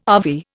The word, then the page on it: ave: Indian